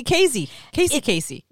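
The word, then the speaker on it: Kasey Kazee: Sounds like Casey Casey. Casey. – It's Casey Casey. – Oh, my. – Guys, this man was doomed from birth. His name is spelled Casey, K-A-S-E-Y, and then his last name is K-A-Z-E-E.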